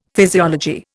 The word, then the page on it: physiology: Indian